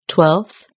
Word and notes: twelfth: British English

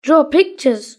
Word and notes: draw pictures: British English

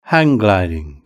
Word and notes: hang gliding: British English

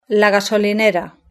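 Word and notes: la gasolinera: Spanish (Spain)

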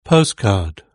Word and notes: postcard: British English

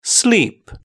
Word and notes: sleep: British English

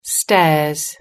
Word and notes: stairs: British English

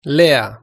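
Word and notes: lea: Spanish (Spain)

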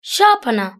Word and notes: sharpener: British English

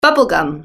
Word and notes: bubble gum: British English

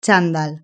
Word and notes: chandal: Spain Spanish

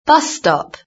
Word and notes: bus stop: British English

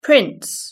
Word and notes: prince: British English

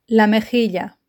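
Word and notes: las mejillas: Spanish (Spain)

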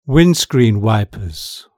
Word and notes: windscreen wipers: British English

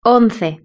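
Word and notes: once: Spanish (Spain)

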